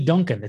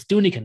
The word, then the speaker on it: Dunican: I'm like, how do you call me Duncan? It's Dunican. There's an I in there.